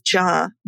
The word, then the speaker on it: Jha: And the last name is Jha, J H A.